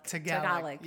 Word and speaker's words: Tagalog: Tagalog. Yep. – Right. – Yep. Tagalog.